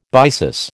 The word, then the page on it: byssus: American English